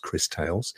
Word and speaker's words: Crystales: It's been mentioned before, but we've now got a release date for it, which is, I think, pronounced Crystales, as in crystals.